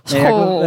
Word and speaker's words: Schorrel: Ik kan nooit zo goed uitspreken. Schorrel. – Ja, Schorrel.